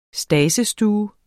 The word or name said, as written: stadsstue